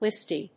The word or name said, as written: listy